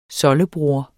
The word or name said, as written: soldebror